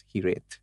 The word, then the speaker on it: Hiraeth: It's called Hiraeth. H, uh, it's it's uh, spelled differently. It's, it's spelled as H I R A E T H, mm-hmm. – but it's pronounced as Hireth.